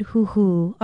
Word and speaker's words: huhu: Even people who do not speak Hawaiian often use the word huhu, our Hawaiian word for today. Huhu is spelled H-U-H-U with a kahako or stress mark over the last vowel.